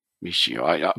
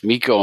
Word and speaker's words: Michio: Michio. I always am afraid it's Miko and Mikio, and I'm always like, is that the way you pronounce it?